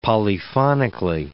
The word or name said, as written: polyphonically